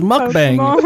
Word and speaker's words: mukbang: Don't let white people pronounce Korean words, please. – Yo, somebody put that in there as a title. Fucking don't fucking spell it. Don't spell it the way it sounds. It's mukbang.